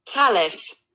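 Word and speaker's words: Thalys: Thales.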